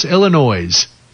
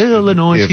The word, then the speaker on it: Illinois: Illinois. And they ended their commercial with OIS because it rhymes with toys.